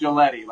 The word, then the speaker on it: Giglietti: It's pronounced Giletti, like spaghetti.